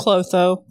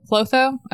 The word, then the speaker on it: Clotho: Following this conversation, they arrived at the library where Rhys introduced Feyre to a priestess named, how do you pronounce it? – Clotho. – Clotho? Okay. Yeah, just how you would think it is.